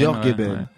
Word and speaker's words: Jorge Ben: Jorge Ben. York ben, voilà. – La vraie prononciation.